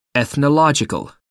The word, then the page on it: ethnological: American English